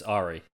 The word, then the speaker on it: Ari: Yeah, it's Ari.